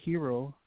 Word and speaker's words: gyro: And, you know, the other time, 2 days ago, I ordered a gyro. I usually like scrambled eggs mixed in with jalapenos. – Oh, you said it right.